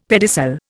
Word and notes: pedicel: Indian

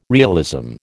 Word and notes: realism: American English

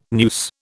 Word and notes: noose: British English

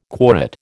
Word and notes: quorate: American English

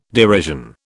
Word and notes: derision: British English

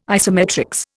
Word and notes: isometrics: Indian

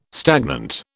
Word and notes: stagnant: British English

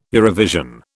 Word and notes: Eurovision: British English

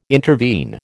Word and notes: intervene: American English

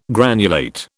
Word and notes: granulate: British English

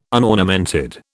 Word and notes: unornamented: British English

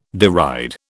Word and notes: deride: British English

